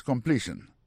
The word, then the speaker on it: completion: Sentence completion. Pour completion dans lui-même, accent tombé sur deuxième syllabe là.